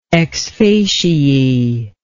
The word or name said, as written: ex facie